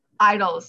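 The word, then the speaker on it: IDLES: Is it Idols? – Idols, yeah. – Nice. All right. Idols, but spelled like I'm idling my car.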